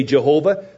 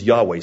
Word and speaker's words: Yahweh: So we don't know today the exact pronunciation. Some say Jehovah, some say Yahweh, other suggestions have been made. People generally, linguistical scholars so-called, generally believe that the pronunciation is Yahweh. So when you see L-O-R-D, look at verse 5, L-O-R-D in capitals, it's Yahweh or Y-H-W-H.